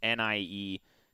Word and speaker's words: Nyheim: The pronunciation we have in his draft player bio is Nyheim. – So we know the heme. – We know the heme. – I think it's Nyheim. – Nyheim. – Nyheim. – That would make the most sense. – Okay. We'll go with Nyheim.